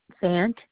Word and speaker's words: Fant: Susan Fant. F is in February, A-N-T. – I'm sorry, can you spell that last name for me? – Fant.